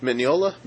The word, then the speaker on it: Mignola: You want to go to that Mignola, or do you want to go to did I say his name right? – Well, there, Mignola. – Mignola. Yeah. Mignola. Yes.